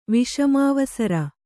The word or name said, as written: ವಿಷಮಾವಸರ